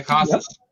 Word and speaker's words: Casas: Casas, am I saying that right? – Yeah, Casas. – Yep. Yep. Casas.